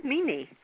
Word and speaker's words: Mimi: Well, if you were a Greek person, you would pronounce it like Mimi. – Ah, okay, Mimi.